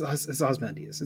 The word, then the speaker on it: Ozymandias: It's Ozymandias. It's the way. It's like you take away the Y. It's, you don't say Ozymandias.